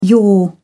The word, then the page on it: yore: English; British English